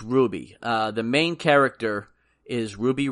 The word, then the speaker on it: RWBY: It's actually pronounced Ruby, and it's issue number one of seven.